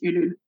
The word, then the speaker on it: Ulule: is it Ulule? Is that how you say it? – Ulule. In French, it's Ulule.